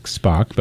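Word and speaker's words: Spok: That was from a Brazilian musician, goes by the name of Spock, and it's S-P-O-K, not the Star Trek Spock, but I think pronounced the same way.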